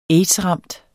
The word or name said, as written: aids-ramt